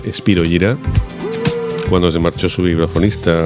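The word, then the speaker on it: Spyro Gyra: La banda DJ Beckenstein, Spiro Gyra, se dice así, Spiro Gyra y no Spiro Gyra, porque ese nombre es un nombre latín, es el nombre de un protozoo, Spiro Gyra.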